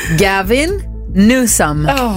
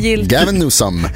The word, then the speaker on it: Gavin Newsom: Gavin Newsom. – Helt korrekt. – Oj. – Fantastiskt kaliforniskt uttal också. Gavin Newsom, guvernör i Kalifornien.